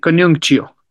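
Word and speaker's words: Coniunctio: Bien, eso fue Conuncio. Así se, ¿sí lo pronuncié bien? – Sí, Conuncio.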